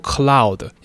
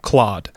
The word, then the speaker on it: Claude: 那首先这个模型呢， C-L-A-U-D-E，不是念作 cloud，也不是念作 cloud，是念作 cloud。 OK，那虽然说如果你是这个英国的人，就是英国腔的话，你可能会念 cloud 之类的 whatever，但反正它的发音是这样。